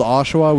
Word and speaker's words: Oshawa: There's this book we're temporarily called Oshawa.